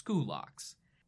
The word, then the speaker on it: Scylax: In English, it's usually Latinized and looks like Scylax, the same way that Scythians became Scythians. But if you know the classical pronunciation, that becomes Skoulax.